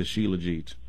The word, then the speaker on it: shilajit: Yeah, and so I think it's spelled S-H-I-L-A-J-I-T, but I think you pronounce it shilajit.